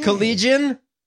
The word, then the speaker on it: Collégien: Is that how you pronounce it? – Collégien. Collégien. – Collégien. It's Collégien.